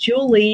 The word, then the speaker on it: Julia: Well, my name is Julie Nall, and my first name is pronounced Julie, even though it's spelled Julia, because mm-hmm. my parents did that to me.